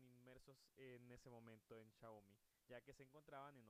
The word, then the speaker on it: Xiaomi: La palabra Xiaomi, que en realidad se pronuncia Xiaomi,